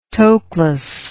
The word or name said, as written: Toklas